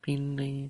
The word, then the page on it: pinli: Lwaitel